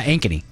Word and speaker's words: Ankeny: She's been a second grade teacher for 35 years in Ankeny, Iowa. I think that's how you pronounce it. – Yeah, Ankeny.